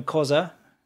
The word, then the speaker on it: Khoisan: I didn't learn Khoisan, that's how you say it.